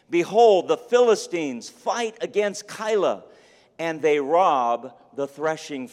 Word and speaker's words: Keilah: And he hears that the Philistines are threatening the people of a particular city named Keilah. K-E-I-L-A-H. Maybe it's pronounced another way, but I'm going to pronounce it Keilah.